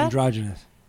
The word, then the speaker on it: androgynous: How is this the word androgeno? How do you say that? – Androgynous.